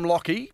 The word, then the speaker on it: Lachy: I'm going from Lockie. – Yep. – And you know it's spelt L-A-C-H-Y. – Yeah, I wish people would get that right. – Yeah, I know. Terrible. Even my dad spells it wrong. He does. He puts the I-E on the end.